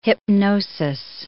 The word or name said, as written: hypnosis